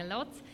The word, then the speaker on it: Loc: Fundacja Loc Arte, nauczyłam się wymawiać, bo kiedyś powiedziałam Łódź, ale jest to specjalnie Loc.